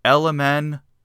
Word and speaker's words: LMNOPLP: He called the record LMNOPLP, so that's spelled ELEMEN space OPEE space ELPEE.